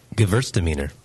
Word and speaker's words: Gewürztraminer: Gewürztraminer.